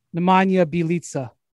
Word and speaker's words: Nemanja Bjelica: Nemanja Bjelica. Did I say it right? – Nemanja Bjelica.